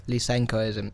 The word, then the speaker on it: Lysenkoism: Lysenkoism.